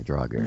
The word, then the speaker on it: Draugr: Yeah, Draugr.